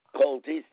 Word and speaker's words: Coltey: Sharon, S-H-A-R-O-N, cold tea, not hot tea, C-O-L-T-E-Y.